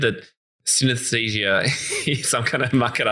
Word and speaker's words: synesthesia: and it's all about synesthesia. Now, have I said that correctly? – Synesthesia.